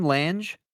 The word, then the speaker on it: Lange: Is that how you say his name? Lange? – Lange. – Oh, I always called him Ted Lang.